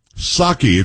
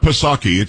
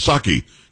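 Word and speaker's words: Psaki: Saki. It's not Pasaki. It was just Saki. You didn't pronounce the P.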